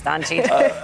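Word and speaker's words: Dončić: Of course I know how to pronounce Doncic.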